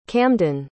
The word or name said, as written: camdyn